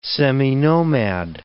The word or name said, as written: seminomad